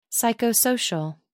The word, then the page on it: psychosocial: American English